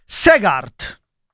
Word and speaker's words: Segaert: Quindi si dice, si scrive Segaert e si pronuncia Segaert.